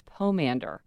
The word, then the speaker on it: pomander: Though I know like, I discovered when I was recording Happier at Home that it's pomander. Also tumult, two words that I've been mispronouncing.